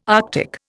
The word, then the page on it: Arctic: Indian